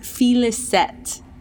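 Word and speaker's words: Félicette: Okay, I think that it is pronounced Felicette. – Oh. – F-E-L-I-C-E-T-T-E. T-T-E, and the first E has an accent on it.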